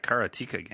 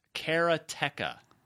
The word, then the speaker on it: Karateka: I'm interested in that Karateka game. – Oh, that's right. I was going to tell you the pronunciation. How right? It's so, right. Straight from Mechner, it's Karateka.